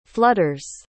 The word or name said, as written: flutters